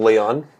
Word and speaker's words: Leone: Am I saying that right, Leone? Is that the correct way to pronounce your last name? – Pretty close. I think Leone.